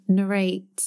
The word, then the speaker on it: narrate: Narrate. How did you say that? – Narrate. – So the emphasis is on a different syllable, isn't it? – Yeah.